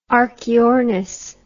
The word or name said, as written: archaeornis